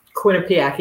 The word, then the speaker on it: Quinnipiac: You went to Quinnipiac University. – Quinnipiac, yeah.